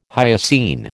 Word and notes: hyoscine: American English

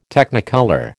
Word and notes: Technicolor: American English

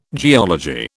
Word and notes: geology: British English

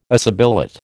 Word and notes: assibilate: American English

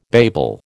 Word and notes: babel: American English

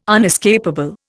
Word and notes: unescapable: Indian

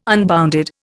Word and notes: unbounded: Indian

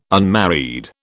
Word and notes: unmarried: British English

